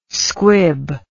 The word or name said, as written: squib